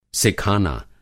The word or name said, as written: सिखाना